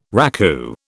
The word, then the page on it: raku: British English